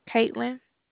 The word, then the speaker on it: Catelyn: Your name is Kate? – Caitlin. – Hold on a minute. Let me write that down. Could you spell that for me? – C-A-T-E-L-Y-N.